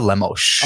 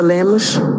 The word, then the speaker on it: Lemos: You're talking about Amanda Lemos. – Amanda Lemos. Let's hear it. – Mm-hmm. Amanda Lemos. Amanda Lemos.